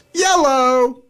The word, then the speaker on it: Iello: I think that you see that's pronounced Yellow.